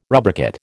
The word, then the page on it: rubricate: American English